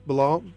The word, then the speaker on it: Bilal: Want to thank Bilal.